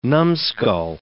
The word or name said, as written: numskull